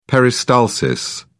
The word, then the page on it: peristalsis: British English